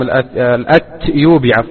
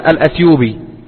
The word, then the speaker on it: الأتيوبي: ومن طرائف ما يروى أن شيخنا الشيخ العلامة محمد بن علي آدم الأتيوبي الأتيوبي يكتب في كتاباته وفي كتبه بالتاء، بينما السائد في النطق أن الناس يقولون الأثيوبي له ولغيره.